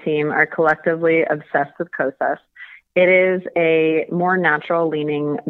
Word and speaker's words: Kosas: Okay, so the second one I wanted to talk about, I don't know if I'm saying this right, Kosas Reveal Concealer? – Yeah, Kosas. – Kosas, okay.